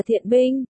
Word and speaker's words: Thiện Binh: Tên của người chồng là Gian Binh, Thiện Binh, chữ Gian có nhiều cách đọc, Chán, Thiền, Gian, Đơn, Sàn, Thiện, ở đây họ của nhân vật đọc là Thiện Binh, bạn Đào Tiểu Sơn miêu tả chữ viết là chữ Gian, Đơn.